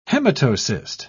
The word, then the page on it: hematocyst: British English